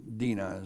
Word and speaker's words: Dinah: Afterwards she bore a daughter and called her name Dinah, or Dinah is the way it would actually be pronounced.